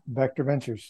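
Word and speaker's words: Vectr Ventures: And am I pronouncing it correctly? Because it's spelled V-E-C-T-R, right? – That's it, Vector Ventures.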